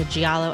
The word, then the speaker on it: giallo: Is it pronounced giallo or giallo? – Giallo. – Just like yellow.